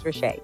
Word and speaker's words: Ritchea: Yes, it's a popular pronunciation. I get Ritchea, I get Ritchie, and the list can go on. – Yeah. – So, the appropriate pronunciation is Ritchea.